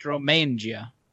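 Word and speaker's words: Romagna: Though it's the grape of most central Italy from Romania, I guess, down to Lazio, Campania. – No, it's pronounced Romangia.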